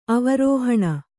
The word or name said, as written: ಅವರೋಹಣ